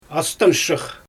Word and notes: astanshyx: Xyzyl

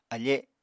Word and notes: afe: Apatani

